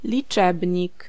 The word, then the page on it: chiffre: France; [ʃifʁ]